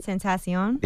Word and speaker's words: Tentacion: Tentacion.